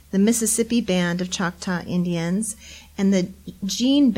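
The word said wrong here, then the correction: Jena: Band of Choctaw Indians. Am I saying that right? – It's the Gina.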